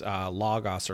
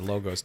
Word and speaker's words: logos: I mean, when you think about that philosophical side of it, I mean, the word that he uses, logos or logos, depending on how you prefer to pronounce yeah. or mispronounce it. I've heard it both ways and I'm okay either.